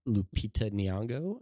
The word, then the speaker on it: Lupita Nyong'o: Uh, but yeah, so then he moves on, and it has the hots for the kindergarten teacher, played by Lupita Nyong'o.